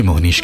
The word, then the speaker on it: Mohnish: मोहनीश